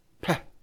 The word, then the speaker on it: pain: For example, the English word bread, what we toast for breakfast, what we make sandwiches out of, bread, in French is pronounced peh, P-E-H, peh. It's spelled P-A-I-N. We would pronounce that pain, but the French pronounce it peh.